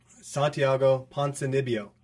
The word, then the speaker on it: Ponzinibbio: Santiago Ponzinibbio. Okay, that's how you say his name.